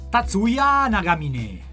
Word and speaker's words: Tatsuya Nagamine: Chiediamo a tuo marito come si pronuncia. – Tatsuya Nagamine.